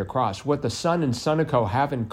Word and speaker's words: Sunoco: Sunoco, it's pronounced.